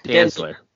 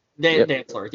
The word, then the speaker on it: Dantzler: Dantzler. – Dantzler, yeah, thank you.